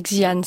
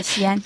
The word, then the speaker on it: Xi'an: Je vais très mal prononcer, mais Shenzhen et Xi'an, c'est ça? Mais vous avez bien prononcé. Je le dis. – Xi'an.